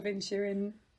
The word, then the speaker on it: Inisherin: And The Banshees of Inisherin. Did I say it right? – You said it perfectly.